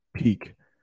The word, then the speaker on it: pique: I mean, that's how it's pronounced. It's kind of a weird word, isn't it? Peak. Peak.